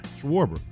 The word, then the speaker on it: Schwarber: Schwarber. Yeah. – It's so hard at the finish of his name. – You had it. – I want to put a T at the end of it. Schwarbert.